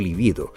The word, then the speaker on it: libido: Libido, que significa deseo sexual, es una palabra grave, por lo cual se desaconseja utilizar la forma esdrújula lívido.